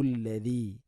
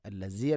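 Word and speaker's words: അല്ലദി (الذي): ഹുവല്ലാഹു അല്ലദീ ഹുവല്ലാഹു അല്ലദീ അല്ലദീ, ദാലിൻ്റെ ഉച്ചാരണം ശ്രദ്ധിക്കുക. അല്ലസി അല്ല അല്ലദി.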